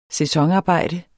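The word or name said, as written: sæsonarbejde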